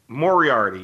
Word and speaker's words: Moriarty: We had a colleague here at SB Nation, Morgan, I can't pronounce her last name, but Morgan Moriarty, I think you're talking about, right? – Moriarty, yes. Sorry, Morgan.